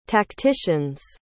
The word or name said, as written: tacticians